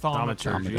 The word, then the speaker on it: Thaumaturgy: Thaumaturgy.